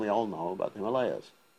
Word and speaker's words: Himalayas: Correct pronunciation, the Himalayas. It was a long A, Himalayas. Himalayas. Anyway, so, see, Om we know about, that is A-U-M, that is Radha, Krishna, and the living entity, which is non-different than the holy name.